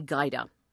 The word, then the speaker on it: Gajda: My name is Amy Guida. My last name is spelled G-A-J-D-A. Now, you might think that I'm the only Amy Guida in the United States, given my not-so-popular first name and the rather odd spelling of my last.